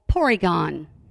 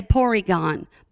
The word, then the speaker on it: Porygon: The monster that was on screen when the incident occurred was called Porygon. For us in America, that would be Polygon, because the Japanese don't pronounce L's, so they say Porygon, but we would say Polygon.